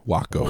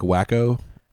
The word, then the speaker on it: Wahcko: It's spelled W-A-H-C-K-O. Waco. – What? – Like Waco. – Yeah. – But they flipped the H and A.